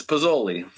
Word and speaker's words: Pizoli: You're very close. It's Pizoli.